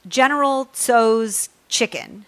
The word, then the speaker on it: General Tso's chicken: General Tso's chicken.